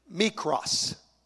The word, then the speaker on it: mikros: Now, the Greek word for little is mikros.